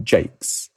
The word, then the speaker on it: Jaques: His name was probably pronounced as a single long syllable in Shakespeare's time, Jaques, which makes the name a homophone for a privy or a latrine.